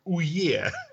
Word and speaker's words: Ouya: Читается как... Точнее, читается правильно как Ouye.